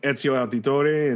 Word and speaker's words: Ezio Auditore: when you were uh you know Ezio Auditore and you know those games were Assassin's Creed.